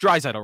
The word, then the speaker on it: Drysdale: And he pronounced Drysdale right.